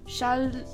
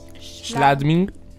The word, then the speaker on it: Schladming: Eh, appunto nel 2013 vinse la medaglia di bronzo a Schal- Schladming.